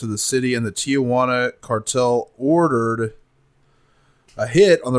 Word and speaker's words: Tijuana: In 1989, Guzman sent Armando Lopez, one of his most trusted men, to speak with the Arellano Felix clan in Tijuana. I'm taking it too far with the pronunciation. Tijuana. Tijuana.